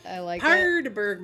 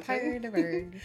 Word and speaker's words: Paardeberg: There's two A's in a row, so I'm just trying to go with that long A noise. – I like it. – Paardeberg Mountain. – Paardeberg.